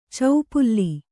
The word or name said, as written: ಚೌಪುಲ್ಲಿ